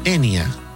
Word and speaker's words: Enya: El mundo la conoce simplemente como Enya. El nombre es apenas la forma inglesa de pronunciar su nombre irlandés.